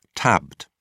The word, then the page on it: tabbed: British English